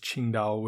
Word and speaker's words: Qingdao: You might know it as those little green bottles of beer that say, you know, like Tsingtao on them, Tsingtao, using the old, I think it's Wade Giles pinyin. We now pronounce it as Qingdao, which is a beach town.